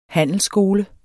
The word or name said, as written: HANDELSSKOLEN